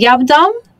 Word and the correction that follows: yab-yum: the position. – Yab-yum, yes. – I was close. – Yeah, you were very close.